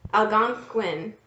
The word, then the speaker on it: Algonquin: Now, this is from, I'm going to say it correctly, Algonquin. – Yes. – Algonquin folklore.